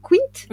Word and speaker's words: Quint: Euh, alors, je sais jamais comment on prononce Quinte Quint. Oui. Quint. Quint. Quint. Quint de Charles Quint en fait. Euh, en fait, il faut savoir que euh, donc euh, à Metz, on est en 1553. Charles Quint,